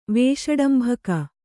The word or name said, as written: ವೇಷಡಂಭಕ